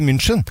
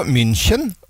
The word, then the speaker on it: München: Det jeg har hengt meg opp i, det uttale på Bayern München. – Ja, du sier München.